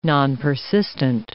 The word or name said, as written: nonpersistent